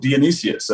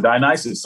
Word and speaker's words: Dionysus: You know, this, the, you know, the festival of Dionysius, or Dionysus, some people pronounce it.